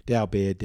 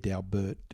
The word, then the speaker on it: Daubert: Daubert, Daubert, depends where you come from, how you pronounce it.